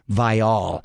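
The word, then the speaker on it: Viall: By the way, Nick Vial, they are now pronouncing his last name Vial. – What? – Yes, and he made it a point to tell everyone that they've been pronouncing it wrong. It's actually Nick Vial.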